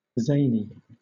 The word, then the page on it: zany: British English